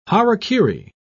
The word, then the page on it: harakiri: British English